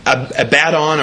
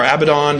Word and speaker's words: Abaddon: And there's a number of words like Sheol, Abaddon or Abaddon, depending on how you pronounce it, the pit, going down.